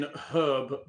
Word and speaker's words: herb: British English doesn't pronounce the H and herb. – We do pronounce the H in herb because it's fucking there.